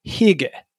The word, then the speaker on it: hygge: It's a Danish concept called, I think this is how you pronounce it, hygge. H-Y-G-G-E.